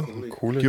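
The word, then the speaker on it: Kulick: Dort drauf spielen aber auch Leute wie der Jean Bonnoir, der Bruce Kulig oder Kulig, wie spricht man es aus? Ihr? – Kulig. – Kulig. Ja.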